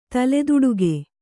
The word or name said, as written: ತಲೆದುಡಿಗೆ